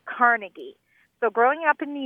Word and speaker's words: Carnegie: Carnegie, accent on the second syllable. – With more of an A than an E.